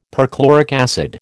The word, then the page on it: perchloric acid: American English